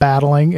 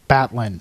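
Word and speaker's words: Battlin': Very important, the pronunciation. It's not battling. It's Batlin.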